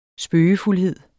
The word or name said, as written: spøgefuldhed